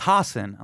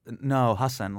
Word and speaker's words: Hasan: When I would go to school and I would say, hey, my name's Hasan, how are you? They'd be like, Hasan. I'm like, jeez. All right. No, Hasan.